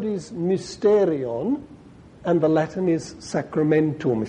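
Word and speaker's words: mysterion (μυστήριον): But so this word is pronounced mysterion, mysterion, y, mysterion. Just like the Y it's called, in fact.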